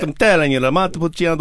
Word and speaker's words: Montepulciano: Montalcino. Is that right? Did I say that right? – Montepulciano.